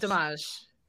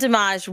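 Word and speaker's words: Demange: But Jan, forgive me if I say this wrong, okay? Dimash? – Yeah, he's French, so I'm going to go with Dimash. – Okay, yes. Jan Dimash will direct Blade.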